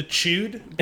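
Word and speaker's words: C.H.U.D.: Sorry, you threw me off a little with the chewed. I know that they like did pronounce it with kind of like a sharper U sound, but until that time, I always assumed it was chud.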